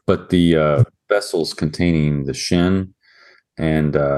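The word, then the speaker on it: shin (שׁ): And if you have a left accented shin character, it's pronounced sin with no H. And if it's a right accented shin character, it's pronounced shin, which is the redemptive thing, which, for instance, we see in the name Shem, for instance.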